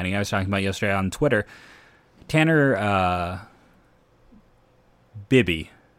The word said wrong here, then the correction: Bibee: right? No, or is it Tanner Bibee. I've been saying it wrong.